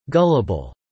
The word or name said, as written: Gullible